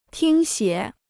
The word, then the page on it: 听写: tīng xiě